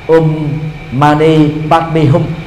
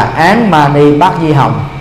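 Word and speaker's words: Om mani padme hum: câu thần chú quen thuộc nhất trong cộng đồng tây tạng là um mani padmi hum hán việt à, tại việt nam chúng ta đọc là án mani bát di hồng nó xa nhau trời và vực rồi à.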